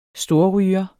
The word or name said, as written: storryger